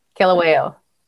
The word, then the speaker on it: Kilauea: Kilauea.